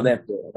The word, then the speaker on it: Udaipur: I, am going to butcher the pronunciation, so I'm, I'm deeply sorry, but uh, uh Udaipur in in Rajasthan. Yeah, Udaipur, Rajasthan.